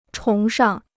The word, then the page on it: 崇尚: chóng shàng